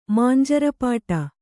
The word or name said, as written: ಮಾಂಜರಪಾಟು